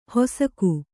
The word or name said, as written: ಹೊಸಕು